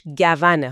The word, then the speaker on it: گون: خیلی خوشحالیم که امروز اینجاییم تا با هم یه نگاه دقیق‌تر بندازیم به منابعی که درباره گیاه گون صحبت می‌کنن. حواستون باشه که تلفظش گونه ها، با فتحه روی گ و و.